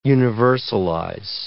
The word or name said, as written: universalize